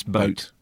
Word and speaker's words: Boot: And while we're on the subject, Das Boot is pronounced Das Boat, just like in English.